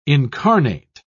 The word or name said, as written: incarnate